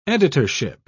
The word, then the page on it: editorship: British English